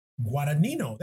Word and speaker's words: Guadagnino: Luca Guadagnino. Guadagnino. Guadagnino.